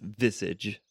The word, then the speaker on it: visage: It bounces off this visage. Vigises. How do you say that? What did you say? That's a great word.